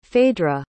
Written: phaedra

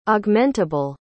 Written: augmentable

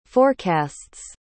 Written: forecasts

